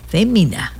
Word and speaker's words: Fémina: El 10, jueves 10, Fémina, acento en la E.